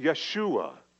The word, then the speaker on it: Yeshua: Jesus is actually the Greek name for the Hebrew Joshua, or as they would pronounce it, Yeshua.